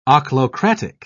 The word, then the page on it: ochlocratic: British English